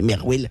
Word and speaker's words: MRWL: Du coup, moi, au début, que je connaissais pas trop Marwal, je me dis, mais comment on prononce ça? En fait, c'est juste, on peut prononcer Merwell.